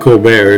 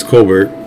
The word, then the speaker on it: Colbert: It's not Colbert, it's Colbert. Even Stephen Colbert will tell you that's the correct pronunciation of his name.